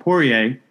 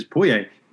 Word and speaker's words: Poirier: I've been calling him Poirier, which is definitely not how to pronounce it. It is Poirier. And even though I took French, I should have known that.